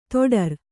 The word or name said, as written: ದಡಾರ